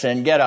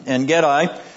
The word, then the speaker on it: En-gedi: Yes, En-gedi. I'll use the Hebrew pronunciation. It's a long I. En-gedi. Where we left David in chapter 23, we now begin with David in chapter 24.